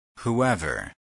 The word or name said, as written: whoever